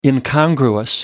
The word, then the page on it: incongruous: American English